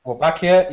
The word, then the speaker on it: Wolbachia: Which, if I practiced, I could pronounce, but it's Wolbachia. – Wolbachia, yeah.